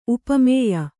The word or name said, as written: ಉಪಮೇಯ